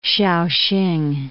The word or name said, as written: Shao-hsing